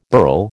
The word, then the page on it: burl: American English